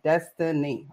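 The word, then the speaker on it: Deztenì: D-E-Z-T-E-N-I. Destiny.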